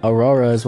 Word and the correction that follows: auras: when in reality it was supposed to be, I was trying to talk about auras, so I just wanted to clear that up and also go back into it.